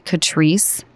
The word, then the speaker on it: Catrice: First of all, I believe you pronounce it Catrice, C-A-T-R-I-C-E.